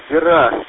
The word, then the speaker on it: Бира: Бира, Бира, на и ударение, Бира, есть у нас такая река. Но если я пропустил свое имя, вы также не сказали свое имя, коллега.